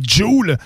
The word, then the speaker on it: Joule: Il y a le rappeur Joule on le prononce comme faux cette fois-ci, man. Faut pas dire Jules, mais bien Joule, qui, prépare un projet qui s'appelle Classico Classico Organisé, qui oh.